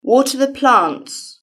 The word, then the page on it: water the plants: British English